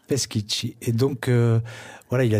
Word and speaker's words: Peschici: Peschici. – Peschici, oui.